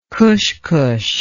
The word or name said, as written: cushcush